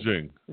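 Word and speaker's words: XING: Is this Zing or Zang? I'm not sure. It's X-I-N-G. – Zing. – Zing, is that how it's pronounced?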